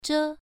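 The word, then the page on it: zhe: tone 1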